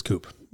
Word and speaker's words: coupe: So jokes aside, American pronunciation of coupe is coupe, right? It rhymes with shoop, which is why Salt and Pepper, let me take you for a ride in my coupe, you make me want to shoop.